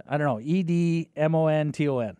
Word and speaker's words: Edmonton: Edmonton. How do you say it? – Edmonton. – Edmonton. It's like Edmonton. – Rhymes with badminton.